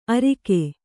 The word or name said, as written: ಅರಿಕೆ